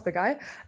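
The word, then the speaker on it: Begaj: Und ja, ihr hattet ja Bayram Begay, also so spricht man den aus, Begay,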